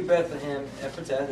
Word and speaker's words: Bethlehem Ephratah: It says this, "But you, Bethlehem Ephratah," did I say that right? – Yes,